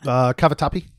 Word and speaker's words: cavatappi: Cavatappi.